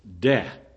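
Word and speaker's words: Deh (δέ): The word and there is delta epsilon in the original. Deh. That's how it's pronounced. Deh.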